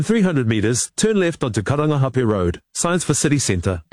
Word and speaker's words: Karangahape: That was TVNZ's Māori Issues reporter Te Aniwa Huri Hanganui driving around Auckland and unimpressed with the navigation voice on the Google Maps app mispronouncing Karangahape Road and other places with Māori names.